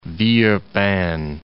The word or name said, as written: Villeurbanne